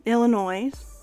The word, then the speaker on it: Illinois: When Congress admitted Illinois, oh my god, I always forget how to say it. Illinois. No S.